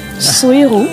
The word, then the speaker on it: Suyru: Suiru. – Oui. – J'ai bien prononcé. – S-U-Y-R-U.